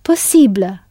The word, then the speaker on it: possible: Using this handy tool, the English possible becomes possible, with the ending pronounced ible in French, and the stress firmly placed on that ending.